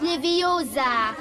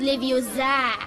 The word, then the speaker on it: Leviosa: Und übrigens hast du die falsche Betonung. Es heißt Leviosa und nicht Leviosa.